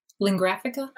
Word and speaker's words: Lingraphica: Lingraphica? – Thank you. Thank you. – No, it's taken me a couple of podcast episodes to figure out how to say their name right.